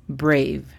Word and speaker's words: brave: La pronunciación fonética es B-O-R-D, bored. La tercera y última es brave. Brave significa valiente. La pronunciación fonética es B-R-E-I-V, brave.